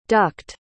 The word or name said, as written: ducked